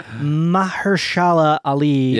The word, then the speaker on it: Mahershala Ali: I'm going to butcher his name. I always get it wrong. Mahershala Ali. – Yes, is, that's pretty close.